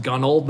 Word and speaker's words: Gunwale: So it's actually pronounced Gunwale, but it's spelled Gunwale, so Gunwale. – All right. – So everyone just says Gunwale, so I just shout out to Gunwale.